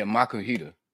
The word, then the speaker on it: Makuhita: I caught a Makuhita. I'm butchering these names. I know it. Makuhita. – Yeah, Makuhita. – Makuhita, okay.